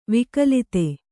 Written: ವಿಗಲಿತ

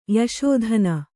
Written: ಯಶೋಧನ

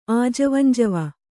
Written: ಆಜವಂಜವ

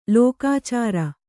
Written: ಲೆಕ್ಕಾಚಾರ

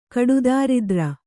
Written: ಕಡುದಾರಿದ್ರ

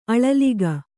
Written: ಅಳಲಿಕೆ

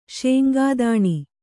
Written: ಶೇಂಗಾದಾಣಿ

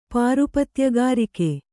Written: ಪಾರುಪತ್ಯಗಾರಿಕೆ

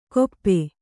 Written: ಗೊಪ್ಪೆ

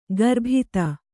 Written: ಗರ್ಬಿತ

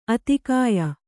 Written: ಆಧಿಕ್ಯ

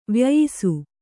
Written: ವ್ಯಯಿಸು

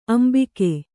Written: ಅಂಬಿಕ್ಕು

